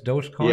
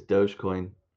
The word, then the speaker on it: Dogecoin: Wasn't it also, what's his name, Mark Cuban, who accepts Dogecoin? – Yeah.